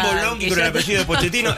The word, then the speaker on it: Pochettino: Yo te lo pronuncio bien, se pronuncia Pochettino.